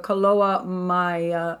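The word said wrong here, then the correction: Koloa maoli: Uh-oh, this is Hawaiian. Sorry for those of you who know how to say this. I don't want to butcher it. Kaloa Maoli. Kaloa Maoli.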